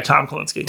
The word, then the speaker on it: Kalinske: Yeah, Tom Kalinske.